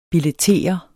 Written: billettere

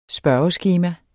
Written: spørgeskema

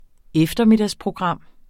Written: eftermiddagsprogram

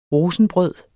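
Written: rosenbrød

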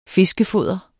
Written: fiskefoder

